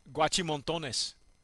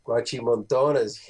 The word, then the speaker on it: Guachimontones: It says it takes its inspiration from the ancient civilization of the, let me see if I pronounce this right, Guachimontones. – Guachimontones,